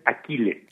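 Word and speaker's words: Achilles: La pronunciación correcta que nosotros utilizamos es Aquiles.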